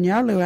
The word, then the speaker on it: Nyarlu: It's Nyarlu. What is a Nyarlu? Nyarlu is a female, woman, and a girl. You can use Nyarlu in their sentence. Hey, you that Nyarlu talking? Or some Nyarlu at the door. Nyarlu is spelt N-Y-A-R-L-U.